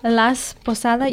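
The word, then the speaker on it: Las Posadas: It is, now, Gabby, am I saying that right? Is it Las Posada? Las Posada.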